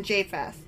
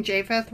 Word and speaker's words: Japheth: So Noah's sons, again, were Shem, Ham, and Japheth. And actually, I've been pronouncing Japheth wrong because the J is a Y. So it's Japheth.